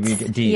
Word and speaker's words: Deets: Deets. Yes. Okay, because it goes with Beats. Rhymes with Beats.